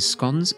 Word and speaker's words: scones: The cream tea is a speciality of Devon and Cornwall, and usually comprises scones, yes, that's how I pronounce it, and cups of tea.